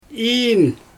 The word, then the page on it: iin: Xyzyl